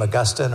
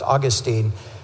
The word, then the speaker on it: Augustine: But of course, if you've heard that name, Augustine, or sometimes pronounced Augustine, many people would recognize him as a leading authority in the history of the church.